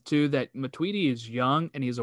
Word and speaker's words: Matuidi: Because if this happens to Tootie, is that how you say it? Matuidi. Matuidi.